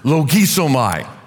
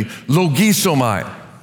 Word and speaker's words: logisomai: Here's how you can remember how to pronounce it. I almost drew this for you, but it's a little bit too much. Imagine standing there and there are some very low-flying geese and they're headed right for you and you're pointing and then there's a bubble over here which says, logisomai. Okay, that's how you pronounce it.